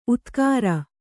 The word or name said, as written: ಉದ್ಗರ